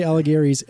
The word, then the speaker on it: Alighieri: It's based on the Inferno segment of Dante's Allegri's. How do you say that? Allegri's. – I think it's Allegri. – Dante Alighieri's epic poem, The Divine Comedy.